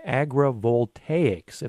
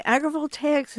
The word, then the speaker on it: agrivoltaics: The area that is emerging here has been given the name agrivoltaics, if I'm pronouncing that right. Introduce us to this area. – Well, you are pronouncing it right. And agrivoltaics has been under study by the Department of Energy since about 2015.